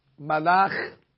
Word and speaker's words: malach: And therefore, as important as tune is, you really should try to keep the pronunciation of the words uh, correct. So melech, malach, yimloch.